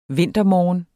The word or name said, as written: vintermorgen